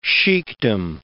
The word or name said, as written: sheikdom